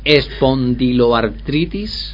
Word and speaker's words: espondiloartritis: a ver si lo digo bien, espondiloartritis. ¿Lo he dicho bien ahora? Espondiloartritis. Sí.